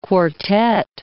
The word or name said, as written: quartet